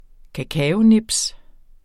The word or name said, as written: kakaonibs